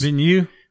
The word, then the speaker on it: Villeneuve: I trust Denis Villeneuve. Is that how it's pronounced? – Villeneuve.